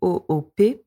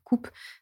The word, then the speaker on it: coop: Pourtant, il existe masse de jeux de coop, alors ça s'écrit COOP pour coopération, et comme vous l'avez entendu, ça se prononce coop et non pas coupe, sous peine de se prendre un hockey boomer dans la face.